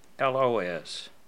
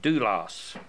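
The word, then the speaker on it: doulos (δοῦλος): The word here for servant is doulos. Apparently it's pronounced doulos. Doulos.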